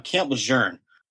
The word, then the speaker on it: Lejeune: I'm not sure how to pronounce it. It's Camp Lejeune.